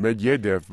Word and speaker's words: Medvedev: Medvedev, but then I worked in Moscow.